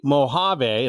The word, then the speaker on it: Mojave: Is it actually Mojave? It looks like Mojave. Mojave. Okay. Generally, you see it spelled with the J, but apparently they anglicized it once upon a time.